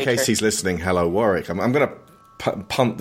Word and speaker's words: Warwick: Warwick. For all you Americans out there, do not pronounce Warwick. the W.